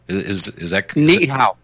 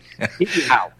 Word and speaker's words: Niihau: Niihau. – Niihau? Okay. – Niihau. There's a glottal stop in there.